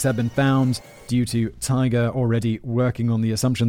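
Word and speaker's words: TIGHAR: In 1988, Richard Gillespie, founder of the International Group for Historic Aircraft Recovery, that's a mouthful, handily pronounced Tiger, which is a cool and better name, got on the case.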